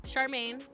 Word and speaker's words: Charmaine: Yeah, I'm Charmaine. A lot of people can't say my name. They mess it up. I've been called Charlemagne so many times.